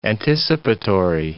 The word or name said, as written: anticipatory